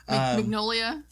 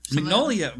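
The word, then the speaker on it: Mignogna: How do you say his last name? – Magnolia. – Magnolia, but Magnolia. It's not spelled like Magnolia, like Mike Magnolia, creator of Hellboy. It's not spelled like that.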